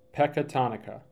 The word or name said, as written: Pecatonica